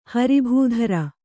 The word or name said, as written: ಹರಿಭೂಧರ